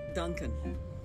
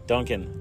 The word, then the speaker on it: Donken: How do you say it? By the way, is it spelled D-O-N-K-E-N? – Duncan. – Duncan. Then my joke doesn't work.